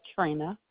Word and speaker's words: Trenna: Trina. – Spell it for me, please. – T-R-E-N-N-A.